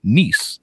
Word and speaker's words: Nice: Uh, and her, uh, she spends time with her family between Atlanta, London, and Nice.